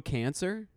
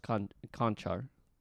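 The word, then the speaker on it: Vlatko Čančar: That's fucking Oh, Kanchar. – What is it? – It's pronounced Conchar. – It's looking like I'm on my screen. It looks like Vlatko Cancer.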